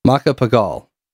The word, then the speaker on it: Macapagal: Macapagal. – Macapagal. That sounds right. – Macapagal, 1961. – Oh, the people of the Philippines make their decision whether to elect Liberal Party candidate Diosdado Macapagal as president or retain incumbent Carlos Garcia.